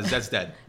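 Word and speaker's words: Zeds Dead: how do you pronounce them? – Zed's dead.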